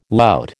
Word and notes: lout: American English

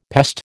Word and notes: pest: American English

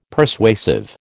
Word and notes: Persuasive: American English

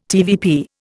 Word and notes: TVP: Indian

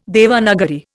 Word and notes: Devanagari: Indian

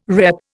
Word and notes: repp: Indian

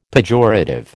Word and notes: pejorative: American English

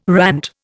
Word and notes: rant: Indian

